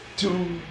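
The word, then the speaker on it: Toogoodoo: Tuckadoo.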